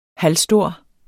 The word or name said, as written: halvstor